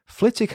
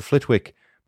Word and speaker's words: Flitwick: Number one, Flitwick has a silent W in its name. Whilst writing the script for this episode, I was convinced that the town's name was pronounced Flitwick, as in Professor Flitwick, but alas, I was wrong. It's pronounced Flitwick, despite being spelled F-L-I-T-W-I-C-K.